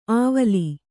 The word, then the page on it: ಆವಲಿ: āvali